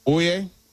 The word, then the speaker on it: Oia: And let me tell you, if you go to Santorini, my suggestion would be to stay in the Oie, which okay. is spelled O-I-A.